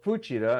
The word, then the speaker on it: Fuchida: So when I came across this one on Fuchida, or Fuchida, as they pronounce it in Japanese, I thought this has got to be done.